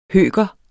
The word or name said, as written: høker